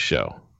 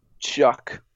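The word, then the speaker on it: Shough: So I just feel like in a way it's pronounced Shuck. I always thought it was Show. – Shuck.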